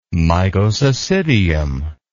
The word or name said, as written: mycocecidium